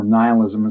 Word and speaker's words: nihilism: so philosophically, nihilism or nihilism, as some people pronounce it, mm-hmm.